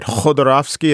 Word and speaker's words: Khodorkovsky: Citizen K is the story of Mikhail, I want to pronounce it right, Khodorkovsky. It's like the K and the sort of sounds like an H when you say it in Russian.